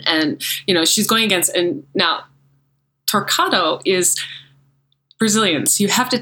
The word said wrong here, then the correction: Torquato: So is it Torcado or how do you say her last name? Diana. – Torquato. – Torquato. – Yeah, if you say it, you could say it Americanized, I'll allow it. Mm-hmm. yeah, it would sound like Torquato, but you do pronounce the U. – Torquato. – Yeah, it makes a qua sound.